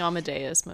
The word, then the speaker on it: Amadeus: I do not know how to say his middle name, sorry. – Wolfgang Amadeus Mozart. – Amadeus, okay. Amadeus. Anyways, he once wrote a 6-part canon titled Lek Mik Im Arch.